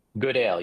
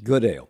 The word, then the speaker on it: Goodale: Trevor, you spoke with James Goodall. I think that's how you say his name, Goodale or Goodall. – Yes. – Say it again. – Goodale, yes. – Goodale, a former vice chair and general counsel of the New York Times during the Nixon administration.